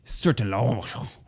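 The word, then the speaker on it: Sertillanges: It's because I think I said Sertalanges or something like that. Isn't that what I said Let's be honest. Pronunciations is not your strong suit. – That's correct. Sertalange. – Sertalange. – Yes, it's because it's French.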